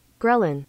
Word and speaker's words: ghrelin: Ghrelin.